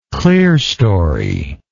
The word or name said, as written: clearstoried